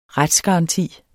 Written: retsgaranti

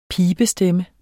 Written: pibestemme

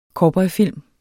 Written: cowboyfilm

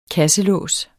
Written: kasselås

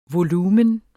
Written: volumen

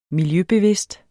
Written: miljøbevidst

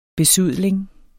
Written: besudling

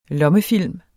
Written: lommefilm

